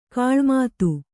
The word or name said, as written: ಗಿಳಿಮಾತು